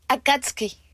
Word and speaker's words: Akatsuki: Akatsuki.